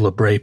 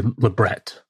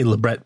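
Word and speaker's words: labret: Everybody calls it Lebray piercing. The word's actually Lebret. But if you say Lebret piercing, you pronounce it properly in French, people look at you like you're crazy. They want you to do the fake affectation of French and call it a Lebray.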